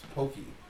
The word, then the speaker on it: poke: Yo, tell the people about how you couldn't pronounce poke. – Poke bowl. – Yeah, it's pokey.